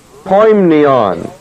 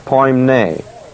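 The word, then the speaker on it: poimnion (ποίμνιον): Well, the word flock here is the Greek word poimnion. P-O-I-M-N-I-O-N, poimnion. It is not the Greek word poimne. P-O-I-M-N, long E. P-O-I-M-N, long E. It's poimnion, not poimne. Poimne is a flock, the term used of a flock, like a flock of sheep.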